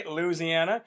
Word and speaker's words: Louisiana: Laura Lado from Louisiana, or Louisiana, right? We got to say it right, Louisiana.